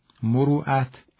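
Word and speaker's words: مروءة: مروعت همون مروتی است که ما در فارسی میگیم. تلفظ صحیح عربیش مروعت هست، از ریشه مرع، مرع یعنی مرد، مروعت یعنی مردانگی و فتوت.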